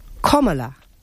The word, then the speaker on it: Kamala: Sí, se lo he escuchado a una especialista que dice que es Kamala. – Kamala. – Como se pronuncia la coma del signo de puntuación, coma, pues Kamala.